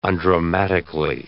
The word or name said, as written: undramatically